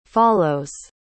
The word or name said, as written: follows